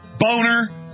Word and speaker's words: Boehner: The name is German. It's a nice name. It's pronounced Boner. And his whole life, he even had an ad campaign saying it's Boehner, not Boner.